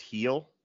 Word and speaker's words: Gil: You know what's fun is that Luis Gil, it's pronounced heel.